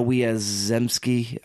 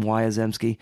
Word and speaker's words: Wiazemsky: Uh, Anna Wiazemsky, I think is how you say her last name, Wiazemsky, uh, who later married Jean-Luc- hyphen Godard.